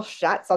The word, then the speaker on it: Schatz: I'm really excited to have Rachel Schatz on the call today.